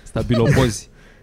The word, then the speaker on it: stabilopozi: Da, pe la stafilococi. – Așa am vrut și eu să zic, am pus să-i pronunț. – Stabilopozi.